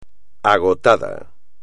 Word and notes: agotado: espagnol

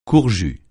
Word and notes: court-jus: French

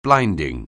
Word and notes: blinding: anglais

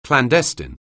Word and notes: clandestine: anglais

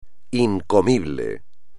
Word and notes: incomible: espagnol

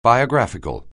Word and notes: biographical: anglais

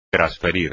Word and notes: trasferir: espagnol

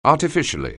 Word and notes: artificially: anglais

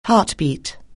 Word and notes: heartbeat: anglais

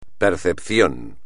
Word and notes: percepción: espagnol

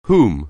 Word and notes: whom: anglais

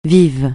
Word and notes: vieux jeu: francais

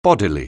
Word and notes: bodily: anglais

